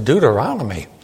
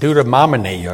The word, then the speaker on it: Deuteronomy: One lady in our church, she said her favorite book was Deuteronomy. She didn't even pronounce it right. I think she said Deuteromony or something.